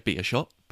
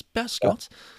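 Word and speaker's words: Beerschot: I didn't want to say beat a shot, but it's Bearscott.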